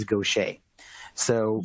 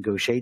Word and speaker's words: Gaucher: one develops the disease Gaucher. So basically, mutations in the GBA gene are the link between Gaucher disease, some pronounce it Gauchers,